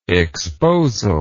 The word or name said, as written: exposal